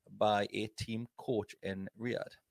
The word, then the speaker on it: Riyadh: Riyadh, Saudi Arabia, Riyadh.